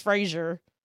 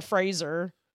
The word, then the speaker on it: Fraser: Yeah, I've been like mispronouncing Brendan Fraser forever. I thought it was Fraser. It's Brendan Fraser.